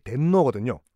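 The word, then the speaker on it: 덴노거든요 (天皇): 그 사람들의 공식적인 명칭은 아니기 때문에 그래서 그들이 부르는 천황의 일본식 발음이 덴노거든요. 그래서 저는 지금부터 덴노라고 할게요.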